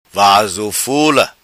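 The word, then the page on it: Vazo Fula: patua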